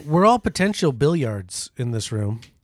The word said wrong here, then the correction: milliards: You didn't say that right. – Isn't it billiards? – Huh? It's milliards.